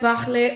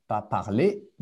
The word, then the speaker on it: parle: Je parle un peu français. – OK, très bien. Donc, juste la prononciation, parle. – Parle. – Parle, très bien. OK, pas parler, mais je parle.